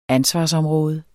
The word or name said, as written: ansvarsområde